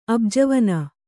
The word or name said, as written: ಅಬ್ಜವನ